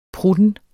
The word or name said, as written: prutten